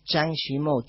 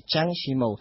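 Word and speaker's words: Zhang Yimou: No, una cosita, que quizá el director más famoso, ya que preguntaban por cine, que se consigue es Chang Shimou. Chang se escribe con Z-H, Chang Shimou.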